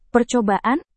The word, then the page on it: percobaan: Indonesian